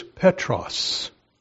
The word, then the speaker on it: Petros: And the Greek word he used there is Petros.